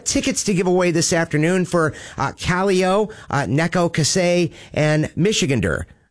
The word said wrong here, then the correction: Neko Case: Oh, I'm sorry. That is Kaleo, Neko Case, and Michigander.